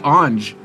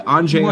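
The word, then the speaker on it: Anže: Just don't say Ange. People get mad at you when you say Ange Kopitar. But Anze, I think, is okay.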